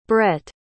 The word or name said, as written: brette